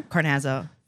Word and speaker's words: Carnazzo: Carnazzo.